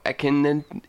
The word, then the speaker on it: Akhenaten: The list includes the late Egyptian pharaoh, Akhenaten. – How you spell that? – A-K-H-E-N-A-T-E-N.